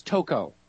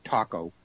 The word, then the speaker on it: Toko: Her name is Toko, not Taco. And I wasn't saying taco either, so emphasis on the wrong syllable.